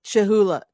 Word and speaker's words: Cholula: Is it Chahula? – Cholula. – Cholula. – Cholula. I'm saying it wrong, I'm sure.